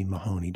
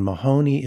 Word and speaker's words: Mahony: My name is William Callie Mahoney. W.K. And Mahoney is spelled M-A-H-O-N-Y. Many families spell that name with an E in it. We do not.